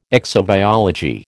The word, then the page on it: exobiology: American English